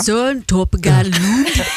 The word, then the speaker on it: Søren Torpegaard Lund: Ja, Søren Torpegaard Lund. Du hade fint danskt uttal där. – Jag tycker det är Søren Torpegaard Lund.